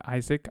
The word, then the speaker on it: Isac: You can find me anywhere on the Twitter sphere at Isaac, I-S-A-C, with just one A, Mihangos.